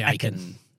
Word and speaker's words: Achan: Well, we say Achan.